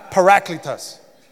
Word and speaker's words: parakletos: And the word for helper in the Greek is parakletos. Some people pronounce it parakletos, but they don't speak Greek like I do. Parakletos.